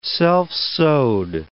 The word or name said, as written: self-sowed